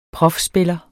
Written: profspiller

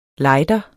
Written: lejder

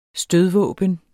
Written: stødvåben

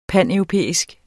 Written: paneuropæisk

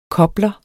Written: kobler

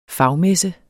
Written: fagmesse

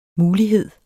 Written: MULIGHEDERNES

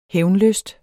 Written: hævnlysten

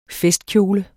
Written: FESTKJOLE

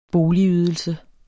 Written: boligydelse

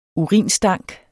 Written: urinstank

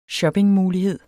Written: shoppingmulighed

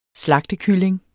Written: slagtekylling

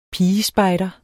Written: pigespejder

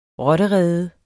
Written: rotterede

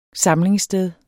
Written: Samlingssted

